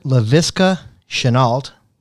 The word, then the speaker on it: Laviska Shenault: Laviska Shenault.